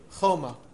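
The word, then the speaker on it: choma (חומה): Right, it's pronounced choma, but it's spelled as if it says chema.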